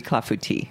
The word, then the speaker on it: clafoutis: And the S is silent. Yes, it ends in an S, but you don't say clafoutis or anything like that.